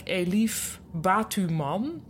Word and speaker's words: Elif Batuman: Eh, de, ik weet niet hoe ik haar naam moet uitspreken, maar ik denk Elif Batuman. Dus het is zeg maar Batman met een U ertussen.